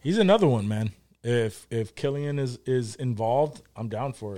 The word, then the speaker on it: Cillian: Killian? – Yeah, Killian. I don't know why I always say Cillian. – That's how I pronounced it for a while, until I heard it pronounced properly.